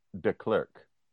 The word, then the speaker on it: De Klerk: You said The Clerk? – Mandela and De Klerk. – Oh, you did not enunciate. There's not enough emphasis on your syllables.